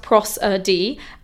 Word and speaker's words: prosody: And prosody, which I've just looked up very quickly, is pronounced prosody, and it's the study of poetic meter and techniques or the vocal patterns in a language.